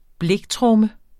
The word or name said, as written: bliktromme